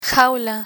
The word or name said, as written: jaula